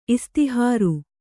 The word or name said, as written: ಇಸ್ತಿಹಾರ್